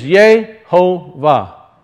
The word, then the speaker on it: Yehovah: and you'll see that its pronunciation is Yehovah.